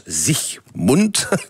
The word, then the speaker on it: Siegmund: Alors, l'homme fort qui a permis cette victoire, c'est Ulrich Siegmund, en allemand ça se prononce Siegmund.